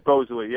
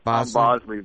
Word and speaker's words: Bosley: Yes, Bosley. Yeah, a lot of people try to pronounce it like Bosley, but...